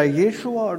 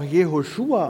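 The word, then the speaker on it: Jeshua (ישוע): Dieser Name Jesus heißt nämlich im Hebräischen, da spricht man das entweder Jeshua oder auch Jehoshua aus, und das heißt Yahweh, nämlich der Gott Israels, der Gott, der Himmel und Erde gemacht hat.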